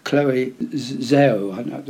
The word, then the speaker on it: Chloe Zhao: Chloe Zhao, that's how you pronounce her name.